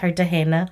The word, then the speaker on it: Cartagena: Some say Cartagena. I think that's how you say it. – Cartagena. – Right? Cartagena. – Uh-huh. – I'll use my Spanish background. – You got that, yeah.